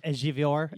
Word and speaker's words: Ejiofor: it's Chiwetel. I can never say his name correctly, but it's Chiwetel. – Chiwetel Il... – Ejiofor. Ejiofor.